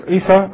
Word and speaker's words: Isa: Jesus, Isa, alayhis-salam, as he's pronounced in Arabic, his name.